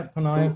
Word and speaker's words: Ponniah: Ponniah, very good.